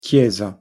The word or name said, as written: chiesa